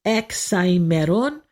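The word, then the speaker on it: Hexaemeron: The hex, H-E-X. We don't really say the H part in Greek. It's Hexaimeron.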